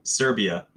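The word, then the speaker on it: Srbija: And that's S-R-B-I-J-A. Can you find that? – Serbia. That's how you pronounce it. – Okay, Serbia. – It's just this, like a Serbian spelling of Serbia.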